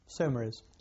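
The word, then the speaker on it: Saumarez: Somaris. – Somaris. – Yes, yes. Somaris.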